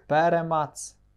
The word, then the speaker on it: перемац: Перемац. – Правильно, перемац. – Такий перемац. наголос. – На перший цей пере.